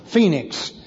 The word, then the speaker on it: Phoenix: And then it goes around a point again and goes up to a place here called Phoenix, which is spelled Phoenici, but it's actually the same as our city in Arizona, Phoenix.